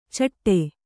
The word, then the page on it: ಚಟ್ಟೆ: caṭṭe